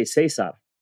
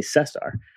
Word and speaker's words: Cesar: So when people see my name, I don't say Cesar because they think I speak Spanish. I say Cesar.